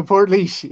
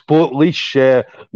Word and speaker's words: Portlaoise: You got it right now, Portlaoise. Yeah, about 20 minutes from my house. – Yeah, so there we go, guys. Portlaoise.